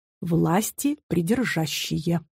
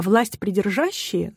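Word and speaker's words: власти предержащие: Соответственно, это словосочетание в смысле «верховная власть» и должно произноситься именно так – «власти придержащие». Власти придержащие – буквально высшие власти, те, которые от Бога. И смысл этого выражения – высокий. А власть придержащие звучит иронично, дескать, схватили ее, эту власть, и не отдают.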